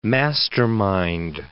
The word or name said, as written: mastermind